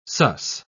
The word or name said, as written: sus